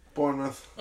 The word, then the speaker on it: Bournemouth: Bournemouth, as Charlie would say. – Bournemouth. – You said it correctly for the first time ever.